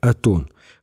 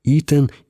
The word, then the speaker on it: Атон: Слово «итн» обычно произносят как «атон», хотя есть доказательства, что его можно читать как «итен» или «иот».